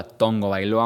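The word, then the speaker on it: Tagovailoa: Y si sí, se pronuncia Tua Tongo Bailoa, aunque ustedes no quieran. Obviamente sé que se escribe Tago Bailoa y no se pronuncia así.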